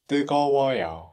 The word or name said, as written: drink